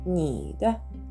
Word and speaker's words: 你的: なのでこの 2 文字で合わせて「にだ」というふうに発音してみてください。